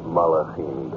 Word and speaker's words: Malachim: From the ancient Hebrew text, we have the words Bene Elohim, the sons of God, Gedushim, the holy ones, and how do you pronounce it? – Malachim. – Thank you, Rabbi.